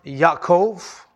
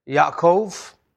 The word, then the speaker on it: Yaakov: Jacob is pronounced Yaakov, Yaakov.